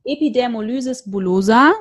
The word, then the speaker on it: Epidermolysis bullosa: Also wir haben schon gehört, du hast Epidermolysis bullosa, so wird es ausgesprochen, ne? Das Genau.